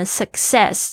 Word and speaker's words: success: Guarantee success，大家注意一下你们的 success 这个成功名词的发音，唱那个 c c 那个第一个呢是 suck，然后呢那个后面这个 c e 呢是 say 啊，所以呢要特别注意一下，两个 c 都是不同的发音。